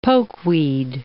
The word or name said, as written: pokeweed